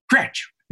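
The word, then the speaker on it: Kroetsch: I said, "How do you pronounce his name?" And he said, "Croce. It's Robert Croce."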